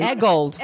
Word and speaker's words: Eggold: I got to meet Ryan Eghorn. – Oh, yeah, he was awesome. – I better get his name right. – Eggold. – Ryan Eggold.